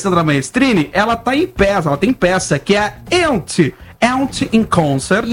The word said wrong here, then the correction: Yentl: Yentl. – Eu vou fazer com pronúncia Yentl.